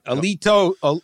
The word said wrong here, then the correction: elote: Alito. How did I say it? – Elote.